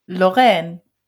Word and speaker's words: Lorraine: Na gut, und wie spricht man das französisch aus? Lothringen? Nee. – Lorraine. – Lorraine, ja.